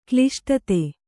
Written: ಕ್ಲಿಷ್ಟತೆ

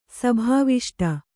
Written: ಸಭಾವಿಷ್ಟ

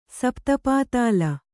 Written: ಸಪ್ತಪಾತಾಲ

